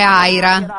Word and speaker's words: Ira: Però è Aira, è un nome di altro tipo, però è vero, è scritto uguale.